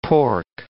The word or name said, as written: pork